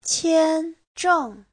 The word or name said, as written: qian1zheng4